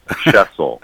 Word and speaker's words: Shesol: Uh, you're very, very close, closer than most people get it. Shesol.